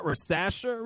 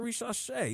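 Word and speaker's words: Risacher: He's French. Not Risache. Risache. He's French.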